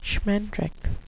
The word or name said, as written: schmendrik